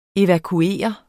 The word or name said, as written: evakuere